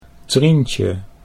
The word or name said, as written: Trientje